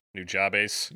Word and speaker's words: Nujabes: In English, it's N-U-J-A-B-E-S, but I believe it is Nujabes, who? Or Nujabes? Nujabes? – Nujabes! – Nujabes. Nujabes, yeah, well known for the, soundtrack for Samurai Champloo.